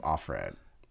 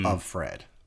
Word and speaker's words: Offred: Yeah, that's why her name was Offred. – Oh, I had no... – They pronounced it Offred, but it was of Fred.